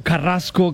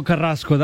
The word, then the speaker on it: Carrasco: It's Gregory Alejandro Carrasco Carrasco. That's how you say it in Spanish.